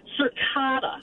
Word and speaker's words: cicada: Cicada. – Cicada. – Not a long A, cicada.